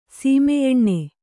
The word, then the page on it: ಸೀಮೆಯೆಣ್ಣೆ: sīmeyeṇṇe